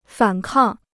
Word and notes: 反抗: fǎn kàng

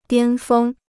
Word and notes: 巅峰: diān fēng

